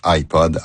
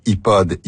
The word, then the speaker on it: iPod: Les noms de ces trois marques de commerce de la compagnie Apple se prononcent généralement à l'anglaise, en l'occurrence iPod, iPad et iPhone. Les prononciations francisées iPod, iPad et iPhone sont parfaitement acceptables, mais beaucoup moins courantes.